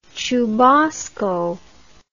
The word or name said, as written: chubasco